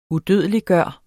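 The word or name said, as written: UDØDELIGGJORTE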